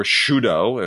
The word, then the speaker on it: Sydow: Of The Diary of Anne Frank with Max von Sydow, or Shudo, as you were supposed to pronounce it.